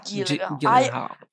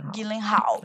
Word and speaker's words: Gyllenhaal: Ai, nunca sei Jake como é que pronuncia. – Jake Gyllenhaal. Gyllenhaal. Né.